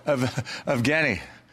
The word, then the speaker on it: Evgeny: Am I saying that right? How do you say his first name? Evgeny? – Oh, Evgeny.